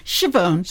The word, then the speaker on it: Schiavone: How do you say your last name? – Schiavone. – Schiavone. But can I say it like that? – You can't. – Even Schiavone, I think.